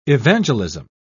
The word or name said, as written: evangelism